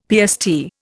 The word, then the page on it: PST: Indian